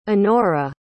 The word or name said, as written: onora